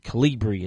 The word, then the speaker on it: Kolibree: Well, the first connected toothbrush is now a reality, and it's from a company called Colibri. That's spelled K-O-L-I-B-R-E-E. Colibri is how I'm going to pronounce it, and it is the world's first connected electric toothbrush.